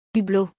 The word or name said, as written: Hublot